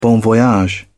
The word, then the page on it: bon voyage: British English